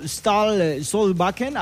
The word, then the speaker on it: Ståle Solbakken: Incluso el director técnico Ståle Solbakken, a ver si lo pronuncié bien, tú que eres el maestro, tú me vas a decir si lo pronuncié bien. – No, hombre, perfecto, tu pronunciación.